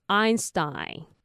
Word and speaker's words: Einstein: 那它跟第三个部分一样，你只要把前面加上 ust，然后那个 T 念成的的音，就是低的音就可以了，就可以念成 Stein。所以他的名字怎么念呢？就是 Albert Einstein。